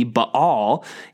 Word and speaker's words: Baal: Well, Baal, or probably again, the more Hebrew pronunciation would be Baal.